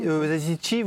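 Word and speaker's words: Yazıcı: Oui, Yazici, vous le prononcez à l'italienne? – Je sais pas. – Moi, c'est je le prononce à la turque, tout simplement.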